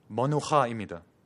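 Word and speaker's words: 머누하입니다 (מנוחה): 발음을 조금 유의하시길 바랍니다. 머누하입니다.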